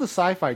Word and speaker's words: Syfy: What they've done is they've kept, so it's the same pronunciation, so it's still the Sci-Fi Channel, but it's now, this is their new logo. It's actually spelled S-Y-F-Y.